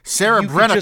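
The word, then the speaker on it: Serebrennikov: You didn't give me a pronunciation for this. Sarah Brenikoff. – Just phonetically. Yeah, you got it.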